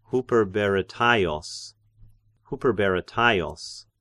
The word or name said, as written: Hyperberetaios